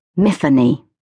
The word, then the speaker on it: Myfanwy: So for me, the W is silent and the F is hard. Thus, miffany. Simple. In fact, now that I think about it, it rhymes with Tiffany.